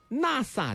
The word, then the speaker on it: NEX: 包含了 NEX 啊，读作 NASA 的超透白 2.0，